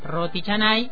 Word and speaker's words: roti canai: El origen del nombre, Roti, roti Chanai, en el idioma malayo, como les decía, se pronuncia roti chanay Recuerden roti canai, por si buscan.